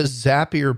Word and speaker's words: Zapier: But what the Zapier people say is Zapier rhymes with happier.